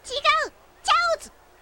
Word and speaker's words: 餃子: これは餃子と読むのかな。 – 違う、チャオズ。